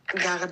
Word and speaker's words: garder: Also das Verb garder wird mit ER hinten geschrieben, aber Garde ausgesprochen.